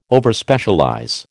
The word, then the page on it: Overspecialize: American English